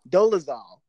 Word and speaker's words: Dolezal: Dolezal.